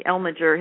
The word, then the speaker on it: Elmiger: Now, there's also in the listserv, and again, I couldn't find it because my computer was off, there's somebody named Elmiger, E-L-M-I-G-E-R, who speaks about tautodes. Are you familiar with him? – Say his name again. – E-L-M-I. G-E-R.